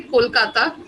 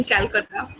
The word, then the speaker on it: Kolkata: So we say Kolkata, but I prefer saying Calcutta.